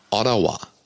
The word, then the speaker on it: Ottawa: Ja, man kann also wusch sich woanders hinbeamen in die Hauptstadt Ontarios, nämlich nach Ottawa. – Oh, hast du das toll ausgesprochen? – Habe ich das toll ausgesprochen? Ja, Wahnsinn. – Ja, Ottawa, sagen wir deutschen Touristen.